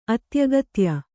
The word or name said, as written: ಅತ್ಯಗತ್ಯ